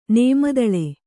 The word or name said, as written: ನೇಮದಳೆ